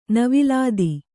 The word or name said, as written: ನೌವಾಲಾದಿ